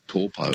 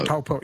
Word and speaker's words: Taupo: Now it's called Taupo. – Taupo, yeah, that's right. It's just the vowel pronunciations are a bit different. They don't really have that ow sound, like cow, meow.